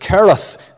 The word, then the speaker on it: Cherith: And the Jewish way would be to pronounce it Cherith, the CH being the hard pronunciation.